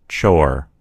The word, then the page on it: chore: American English